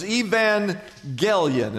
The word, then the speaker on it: evangelion: Now, the word good news in the Greek, you can write this down in your notes, is evangelion, is how you pronounce that, which is where we get our English word evangelism.